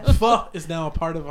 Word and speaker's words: pho: Which is so much fun to say. Pho. I had to look it up to see how to pronounce it.